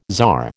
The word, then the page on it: tsar: American English